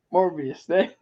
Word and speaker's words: Morbius: Yo, Ray loves the movie Mobius. Confirmed. He loves it so much that I can't even pronounce it correctly. – Morbius? Wait. – Morbius. Yeah. I said Mobius.